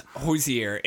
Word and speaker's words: Hozier: So he was born in Ireland, so it's Hozier.